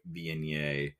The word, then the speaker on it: Viognier: I have a 425 Cellars Picnic Point Viognier. Not too bad. Oh. Not too bad. And yes, I nailed the French pronunciation, so I got that going for me, which is nice.